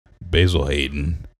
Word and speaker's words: Basil Hayden: Basil Hayden.